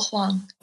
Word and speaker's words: Hwang: Yeah, my name is Nicole Hwang.